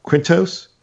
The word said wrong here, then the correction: Quinones: I thought it was Quinones. – Quinones, yeah, thank you.